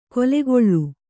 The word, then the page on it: ಕೊಲೆಗೊಳ್ಳು: kolegoḷḷu